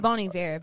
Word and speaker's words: Bon Iver: It's pronounced Bon Iver, but it's fine.